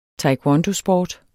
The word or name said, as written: taekwondosport